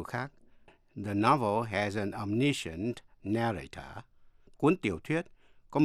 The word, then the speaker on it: omniscient: Chữ omniscient có hai cách đọc. Omniscient có ba âm tiết, đọc theo giọng Mỹ. Và omniscient, bốn âm tiết, đọc theo giọng Anh. Quý vị nghe lại, đọc theo giọng Mỹ. Omniscient. Đọc theo giọng Anh. Omniscient.